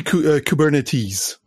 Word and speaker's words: Kubernetes: Puis, c'est drôle comment tu le prononces, Kubernetes. Tu prononces pas le S à la fin.